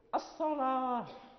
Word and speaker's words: الصلاة: مثلا الاصطلاحي الصلاة، يعني هنا نجد الواو لا ننطق بها، الواو لا ننطق بها، هي الواو مرسومة.